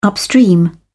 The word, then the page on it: upstream: British English